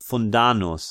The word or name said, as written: Fundanus